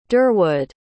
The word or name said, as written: durwood